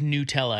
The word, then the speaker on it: Nutella: So back to our NFL Nutella, and it is Nutella. I looked it up. – Oh, okay. I've been saying it wrong all these years, which probably just makes sense because it's me.